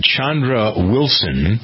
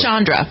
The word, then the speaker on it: Chandra: Later in the hour, we're going to meet somebody named Chandra Wilson. – Oh, you don't say it like that.